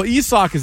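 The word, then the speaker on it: Isaac: Well, Esoc is actually how you pronounce it. Nick was all over that earlier.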